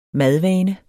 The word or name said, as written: madvane